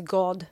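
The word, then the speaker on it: Gordes: G-O-R-D-E-S. I've been told it's pronounced God.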